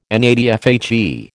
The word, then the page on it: NATFHE: American English